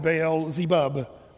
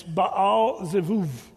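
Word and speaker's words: Baal Zevuv (בעל זבוב): Well, if you're a Hebrew person, you don't pronounce it Beelzebub. It's Baal Zevuv. That's the way the Hebrew people pronounce it. The B there is more of a V.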